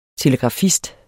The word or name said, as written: TELEGRAFISTER